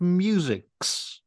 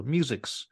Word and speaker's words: MU6: That's I suppose it's supposed it's probably supposed to be pronounced musics label. Musics. Yes, musics No.